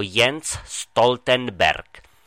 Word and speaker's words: Jens Stoltenberg: A ten Stoltenberg, to je čistá tragédia. To nie je žiadny cukrík, je to Jens Stoltenberg.